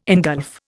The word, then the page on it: ingulf: Indian